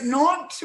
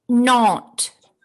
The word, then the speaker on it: Nantes: Is it Nantes with the T at the end? – Yes. Yes. – Can you pronounce that again? I didn't get it. – Nantes.